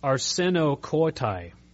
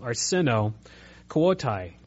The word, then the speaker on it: arsenokoitai: If you look at that term that in the New King James Version is translated as sodomites, that Greek term is arsenokoitai. I'm probably butchering the pronunciation, but it's A-R-S-E-N-O, arsenokoitai, K-O-I-T-A-I.